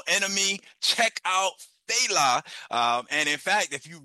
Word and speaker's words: Fela: And if you are not familiar with, well, see, I have the American pronunciation. My Nigerian comrade said it's Fela.